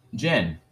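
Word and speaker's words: Djenné: The City of John. Djenne? Wait, let me make sure I said his name right. – Djenne. – Yeah, City of Djenne.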